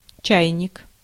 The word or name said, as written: kettle